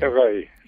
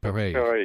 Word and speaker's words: Perret: On the occasion of the death of your friend Janine, I'm not sure how to pronounce the last name. – Perret. – Okay, Perret. – It's a French name.